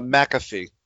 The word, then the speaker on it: McAfee: Is that how you pronounce it? – It's uh, McAfee. – McAfee.